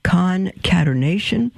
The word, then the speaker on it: concatenation: Caternation, concatenation. Now I'm pronouncing that wrong. Concatenation, um, albeit very remote.